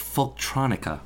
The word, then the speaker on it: folktronica: Yeah, and it really kind of gives me a sense of what folk trance would be like, I guess. And it works really well. – It's pronounced folktronica. – Oh, folktronica. Excuse me.